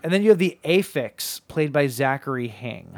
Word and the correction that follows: Asphyx: Asphyx, okay. – Asphyx, because it's supposed to be like asphyxiation.